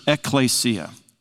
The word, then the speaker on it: ekklesia: In the New Testament, the Greek word that's translated church is ekklesia.